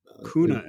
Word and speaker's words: Kuehne: So like I said, you know, it's Kuehne and Nagel. – Kuehne and Nagel.